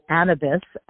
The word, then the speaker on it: Anabee: So Anabees and then style, two words put together, .com. So we call it Anabee because the French pronounce it Cannabee. And if you take off that C, it becomes Anabee.